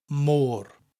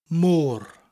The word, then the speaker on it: môr: The letter O in the middle of the word has an accent over it, a little upside-down V shape, which gives us that elongated O sound, môr. It almost sounds like the English word more, M-O-R-E, but has that rolling R sound at the end of it, môr, môr.